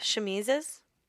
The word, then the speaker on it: chemises: So underneath all the petticoats and all that, women were still wearing like loose fitting underdresses or shimmies. How do you say that? – Chemises.